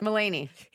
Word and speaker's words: Mulaney: Mulaney.